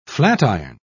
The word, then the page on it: flatiron: British English